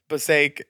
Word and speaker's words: Passaic: Passaic? – Cum dump? Oh, yeah. Sorry. Yeah. Passaic is a town in northern New Jersey.